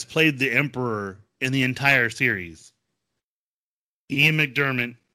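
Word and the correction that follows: McDiarmid: McDermid, I can't I never say his name right.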